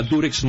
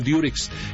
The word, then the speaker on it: Durex: Así se llama Durex, no Diurex. Lo que pasa es que nosotros pronunciamos, al decir Diurex, pronunciamos la U como se pronuncia en inglés, U, okay.